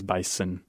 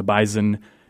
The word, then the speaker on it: bison: And once you get about 70 miles, 100 miles, give or take, away from Fargo, everybody says bison, sounding more like what you'd think of as an S sound, a sound. And it turns out the Z pronunciation, the bison, is local to us and unusual to everybody else in the country.